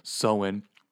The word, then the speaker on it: Samhain: But as I understand it, it's pronounced Samhain.